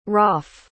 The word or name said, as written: rauf